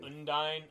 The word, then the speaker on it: Undine: It's either Undine or Undyne. – Undyne. I looked at it Undyne. That's apparently what they call themselves, even though they don't have a spoken language.